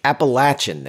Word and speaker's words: Appalachian: How was that before you got into Appalachian State? Is that where you wanted to go? Appalachian State? – Appalachian.